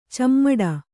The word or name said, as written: ಚಿಮುಟು